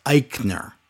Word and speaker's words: Aichner: Fabian Aichner, that's how you pronounce it, Aichner, right? With an I. But it looks like Aichner.